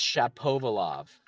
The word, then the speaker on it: Shapovalov: Hold on. Denis Shapovalov. There we go. That's how you pronounce his name.